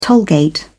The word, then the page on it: tollgate: British English